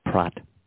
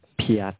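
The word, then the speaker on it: Pyatt: His name's Jeffrey Pratt. No, sorry, Piat. I don't know how to pronounce it. It's P-Y-A-T-T. It should be P-R-A-T-T.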